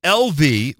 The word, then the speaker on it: Elvi: LV, which is, that's correct, E-L-V-I.